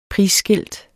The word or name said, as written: prisskilt